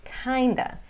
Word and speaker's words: kind of: E a pronúncia é outa, sorta e kinda.